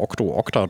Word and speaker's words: Octo Octa: Ich werde es schon noch rausfinden, aber dafür musste ich nochmal Octo Octa durchsagen, weil ich hier immer so Sachen schlecht ausspreche. Sage ich es nochmal. O-C-T-O, O-C-T-A, First Intention, Right Here, Right Now vom Album Sigils for Survival habe ich gespielt.